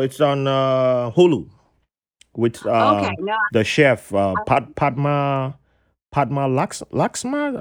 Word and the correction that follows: Padma Lakshmi: I think that's how to say her name. I think I butchered her name. Padma Lakshmi. Yes. Okay.